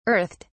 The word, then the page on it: earthed: British English